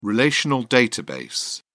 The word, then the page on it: relational database: British English